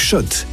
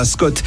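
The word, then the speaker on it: Schott: Jouer et gagner sur RMC avec Shot, la marque iconique new-yorkaise depuis 1913. Oui, Shot et pas Scott. Ça fait des années qu'on prononce mal la marque.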